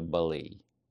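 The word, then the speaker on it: ballet: Кстати, слово «балет» пишется как «балет» по-английски, а читается «балей», «Нью-Йорк Балей», то, конечно, надо заниматься с преподавателем по балету.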